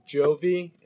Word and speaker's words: Johvi: It's Johvi, J-O-H-V-I, pronounced like the Bon Jovi, the infamous Bon Jovi.